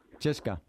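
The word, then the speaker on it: Cheska (ЦСКА): ¿Cómo se dice CSKA en ruso? – Cheska.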